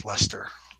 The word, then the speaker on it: Leicester: The Ice, L-E-I, the British spelling. – Yep. – Like the county in L-E-I-C-E-S-T-E-R, and that's how they pronounce that in England is Lester.